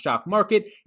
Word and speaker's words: Jock MKT: Jock MKT. It's pronounced Jock Market.